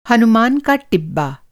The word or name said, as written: टिब्बा